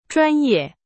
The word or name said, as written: 专业